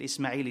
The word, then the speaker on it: Ismail: So Ismail, whether you say it's in Hebrew or it's in Arabic, because the reason why we know it's pronounced Ishmael or Yishmael is in Hebrew, but Ismail is in Arabic.